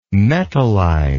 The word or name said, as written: metallide